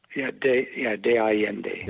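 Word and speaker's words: San Miguel de Allende: He's based out of San Miguel de la, I hope I say this right, San Miguel de la Allende? – De Allende. – De Allende. – Yes, De Allende.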